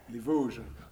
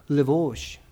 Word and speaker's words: Les Vosges: Les Vosges. – Les Vosges.